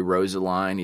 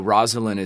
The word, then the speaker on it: Rosalind: So, you know, Rosaline, and I know I'm intentionally mispronouncing it because it sounds more Southern to say Rosaline, even though everybody always gets it wrong, but you got it right. It's you know, Rosalind is the girl at the beginning of Romeo and Juliet that he's like, oh my God, she's the greatest thing.